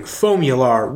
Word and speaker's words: Foamular: How do you say it? – Owens Corning Foamular Rigid Foam Fiberglass Insulation.